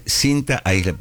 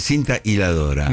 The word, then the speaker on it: aisladora: Es cinta aisladora, porque la mayoría dice cinta hiladora.